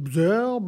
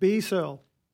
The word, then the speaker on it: Bsearl: I was like, how do I pronounce this guy's name? It's spelled B-S-E-A-R-L. So I'm like, what is it? Bzerl, blah, blah, B-S-L. But no, apparently his full government name is Brandon Searle. So it's literally just B Searle.